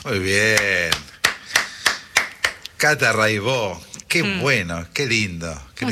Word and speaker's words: Raibaut: Buenísimo, Cata Raibó. Se escribe Raibaus, métanse, búsquenla, Rai con Y, Baut con B larga. Catalina Raibaut, Raibó se dice, y bueno, Cotidiano son 10 canciones, hay un documentalito muy lindo.